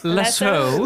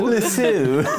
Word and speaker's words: Lasseau: it's spelled L-A-S-S-E-A-U. – Yes. – Lasso. – Lasso.